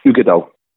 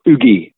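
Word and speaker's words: Uigeadail: And it's spelled weird, so it's U-I-G-E-A-D-A-I-L, so Ugadal, but it's actually Ugadal, so it's just pronounced Ugadal, or Ugi, we all just call it Ugi for short, and then you have Corrie of Wrekin.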